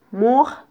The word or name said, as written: muj